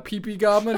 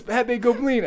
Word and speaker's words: Pepe Goblin: But like, there's just a series of people like mispronouncing his name and calling him Pee Pee Goblin. And he's like, no, it's Pepe Goblin.